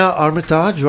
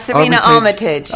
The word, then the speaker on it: Armitage: Did I pronounce it correctly? – Serena Armitage. – Armitage. – Say in the UK. – Armitage, okay. – But you can say it any way you like.